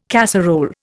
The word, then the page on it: casserole: Indian